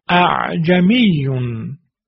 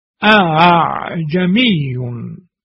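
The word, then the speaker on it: ءأعجمي: اگر هر دو تحقیق می شدن اینطور می شد اعجمیون، اما تلفظ صحیح به این ترتیب هست. دقت بفرمایید اعجمیون اعجمیون. دقت بکنه ببینیم که در همزه دوم ما فقط صدای فتحه رو آوردیم، تیزی همزه رو حذفش کردیم.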